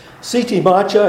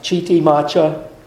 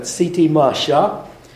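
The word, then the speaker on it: Chitimacha: Siti Masha, it's not pronounced Chiti Masha, but Siti Masha,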